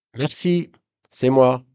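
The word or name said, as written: c'est moi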